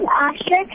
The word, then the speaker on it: Ashir: Is that Ashit? – Yeah. – Thank you. – Ashit. – How do you spell it? – A-S-H-I-R. – Ashir. Okay, thank you, Ashir.